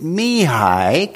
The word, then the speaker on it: Mihai: This is how you pronounce it. Mihai.